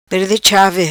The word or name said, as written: Perde Chavi